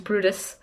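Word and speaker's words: Bryde's: Could you tell us a little bit more about it, the Brutus whale? – So it's spelled Brides. It's like Brydes, but it's pronounced Brutus.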